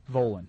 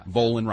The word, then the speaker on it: Volin: It rhymes with colon. – Volin. – You can say his name. – Yeah. – Volin rhymes with colon.